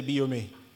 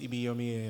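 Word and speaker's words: Ibiyeomie: I was corrected that you pronounced it well in the first service. I say Ibiyomie. It says Ibiyomie, yes.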